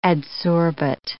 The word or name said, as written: adsorbate